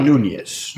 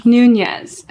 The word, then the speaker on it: Nunez: Wait, so how do you say your last name? – Nunez. – Okay. – Nunez. – Like totally like the white way to say it.